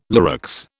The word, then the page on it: Lurex: British English